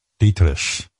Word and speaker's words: Dietrich: I've never heard that pronunciation. – Well, you don't speak German. Dietrich.